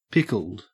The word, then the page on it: pickled: Australian English